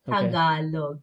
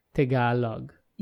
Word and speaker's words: Tagalog: But in the Philippines, the vowels are very blunt, so it's Tagalog. – Okay. Tagalog. – Yes. Very good. Okay. Tagalog.